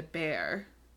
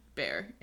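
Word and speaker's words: Béxar: Anyway, so they build the mission and they also establish a military garrison nearby, which is called San Antonio de Bear. And I'm pretty sure that's how you pronounce it. It's B-E-X-A-R, Bear.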